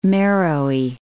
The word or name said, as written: Meroe